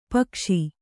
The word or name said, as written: ಪೀಕಿಸು